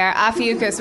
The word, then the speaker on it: Ophiuchus: Am I pronouncing it wrong? What is it? Ophiuchus? – I know, right? – Adam Goldberg to the rescue. – Is it Ophiuchus? – Yep. – Okay, Ophiuchus, which is November 29th to December 17th, and I was born on December 5th, so.